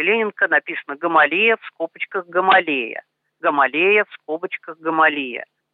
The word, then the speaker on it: Гамалея: Но по радио, насколько помню, до последнего его все-таки называли Гамалия. И мне это более привычно, поэтому в наших двух программах я буду произносить его фамилию как Гамалия.